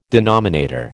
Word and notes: denominator: American English

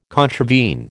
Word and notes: contravene: American English

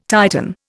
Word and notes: Titan: Indian

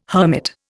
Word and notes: hermit: Indian

